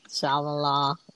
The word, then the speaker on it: Shyamalan: Shyamalan. M. Night Shyamalan.